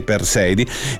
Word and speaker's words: Perseidi: Ma nei prossimi giorni ci saranno, chiaramente, come mi segnalate giustamente, le Perseidi, detto giusto, sì, Perseidi, quindi lo sciame meteorico, le stelle cadenti che saranno protagoniste.